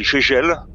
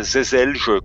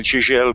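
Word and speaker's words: Žeželj: Euh, et puis dessiné par un de mes chouchous qui est Daniel Gégel et non pas Zezelge comme je le vois parfois prononcé et je suis un petit peu à cheval sur ce genre de choses donc euh, Daniel Gégel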